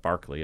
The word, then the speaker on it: Berkeley: And the Grove Press edition has Beckett's copious notes, curious illustrations, quotes from, you know, the Irish philosopher George Berkeley, and Barclay is the correct pronunciation.